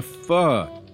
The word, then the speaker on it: pho: No one here, Vietnamese speaking or English, pronounces pho with a long O. Everyone pronounces the soup with a pho sound.